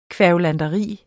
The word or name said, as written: kværulanteri